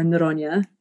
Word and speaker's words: Neronha: Our guest today is Attorney General of Rhode Island, Peter Noronha, or Noronha, I think is the Portuguese pronunciation, if I did that correctly. – Yes, you did.